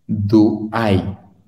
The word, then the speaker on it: Douai: Esto en Francia, Douai, así se pronuncia, Douai. Es difícil, son cuatro vocales juntas. Do, u, ai, do, ai, do, ai.